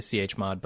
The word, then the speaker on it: chmod: But or chmod, how do you pronounce that? – I'd say chmod, but that's cumbersome.